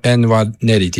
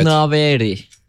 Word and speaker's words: Nwaneri: Nwaneri diye okunuyor mesela. – Yani telaffuzda hata olabilir, evet. Nwaneri diyelim biz.